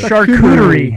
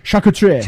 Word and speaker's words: charcuterie: How do you pronounce it? – Charcuterie. – Charcuterie? – Yes. – And say it like Anthony Bourdain. Charcuterie.